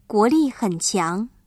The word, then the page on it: 强: qiáng